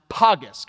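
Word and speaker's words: pagis: In 1 Timothy, the Greek word Paul uses here for trap, it's a Greek word pronounced pagus.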